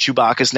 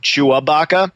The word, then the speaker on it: Chewbacca: In fact, um, I said on Facebook the other day that m- my wife mispronounces Chewbacca's name as she pronounces it Chewabaca,